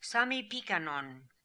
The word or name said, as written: Sami Pikan On